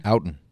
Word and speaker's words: Outten: Outten. – So you'd say ow or ah? Ow. Outten. – Ow. – Outten. – Outten. – Outten. – Yes. – Okay. Outten.